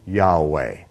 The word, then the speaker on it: Yahweh: the Sephardic or Spanish Jew pronunciation is more to the original, then it would be Yahweh.